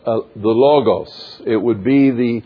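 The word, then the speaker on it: logos (λόγος): In Greek, the word is logos or lagos, as some people say. L-O-G-O-S is how we would spell it in English.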